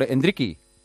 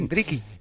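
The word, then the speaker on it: Endrick: Luego te vamos a preguntar por Endriki. – Sí, he escuchado a Melchor este mediodía que se dice así. – Endriki. – Y además su cuenta de Twitter acaba en i, así que hay que creérselo.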